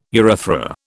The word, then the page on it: urethra: British English